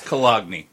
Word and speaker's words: Cologne: I actually, I know how to pronounce that. It's Cologne. Cologne.